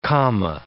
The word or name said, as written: comma